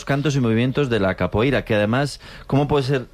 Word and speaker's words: capoeira: Pues todos sabemos lo que es la copeira, ¿verdad? – Capoeira. – Qué mal pronuncio últimamente.